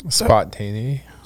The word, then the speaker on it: spontaneity: Spontany? Is that spontaneity? – Spontany? – Spontaneity. – No. – Is that it? – Yeah.